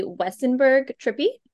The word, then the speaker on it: Wesenberg-Trippy: All right, so here we have, tell me if I pronounced the name correctly, Allie Wesenberg Trippy.